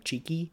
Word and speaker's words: Txiki: Which is spelled T-X-I-K-I, but it is pronounced like cheeky.